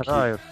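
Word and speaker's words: Riot: Райт, правильно говорить. А? Он Райт. Райт.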